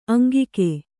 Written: ಅಂಗಿಗೆ

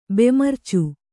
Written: ಬೆಮರ್ಚು

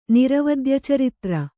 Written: ನಿರವದ್ಯಚರಿತ್ರ

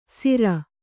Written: ಸಿರ

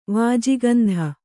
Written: ವಾಜಿಗಂಧ

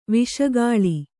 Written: ವಿಷಗಾಳಿ

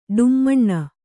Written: ಢಮಣ

